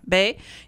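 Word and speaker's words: Beah: Then I went and I got his book because I wanted to see, what you brought up, and that's Ishmael Bey. Is that I'm not sure if I'm pronouncing it right. – Yes, you are. – Bey, yeah, and of course this is a horrific story in many ways of his childhood in Africa, and it's horrific.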